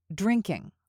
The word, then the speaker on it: drinking: Remember that the G in this word shouldn't be pronounced or emphasized. We don't make the G sound. It should be drinking, drinking.